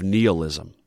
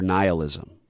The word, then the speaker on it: nihilism: both of these give evidence to the philosophy of nihilism, or nihilism, as it can also be pronounced.